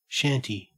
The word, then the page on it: shanty: Australian English